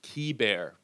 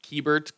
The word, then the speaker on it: Keibert: His name is usually pronounced wrong, but it is Key Bear, like a key, like a door key, and then bear, like teddy bear. Uh, I know it looks like Keybert, Kybert, um, but you don't pronounce that T at the end.